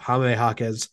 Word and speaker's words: Jaime Jaquez: but Jaime Jaquez,